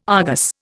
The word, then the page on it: Argus: Indian